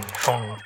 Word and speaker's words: Phaune: I mean, phone radio. It's spelled P-H-A-U-N-E. But it's pronounced phone.